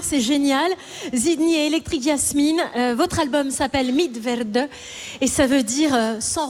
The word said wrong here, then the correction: Mitwerde: Oui, alors ça se prononce Mitwerde, pardon.